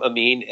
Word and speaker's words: Ameen: My website is Tom Amin, and that's spelled AMEEN.